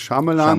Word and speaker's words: Shyamalan: Shyamalan, Shyamalan, ihr dürft ihn aussprechen, wie ihr wollt. Ich glaube, die richtige Aussprache ist Shyamalan. – Shyamalan, ja.